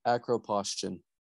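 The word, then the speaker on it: acroposthion: How do you pronounce it? – It's the acropostion.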